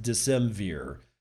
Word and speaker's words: Decemvir: I think it's pronounced Decemvir. D-E-C-E-M-V-I-R.